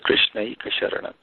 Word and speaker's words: Krishnaika Sharana: When it is combined together, we pronounce it as Krishnaika Sharana.